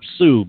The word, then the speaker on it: Sault: Now, for those of you uninitiated or who have never heard of it before you go looking for it on the map, don't look up Sue, because she's not there. It's spelled S-A-U-L-T, Saint, the abbreviation S-T-E, and Marie. Marie is there, but Sue isn't.